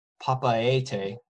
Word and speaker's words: Papeete: And they're heading towards Papeete, which is the capital of French Polynesia, on the island of Tahiti.